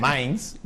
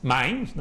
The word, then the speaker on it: Mainz: O Mainz, no sé, allí habrán corregido 30.000 veces cómo se pronuncia. – Mainz. – Mainz, ¿no? Más fácil de lo que...